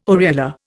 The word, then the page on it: areola: Indian